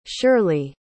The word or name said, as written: shirlie